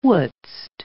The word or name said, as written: wouldst